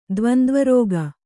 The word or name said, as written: ದ್ವಂದ್ವರೋಗ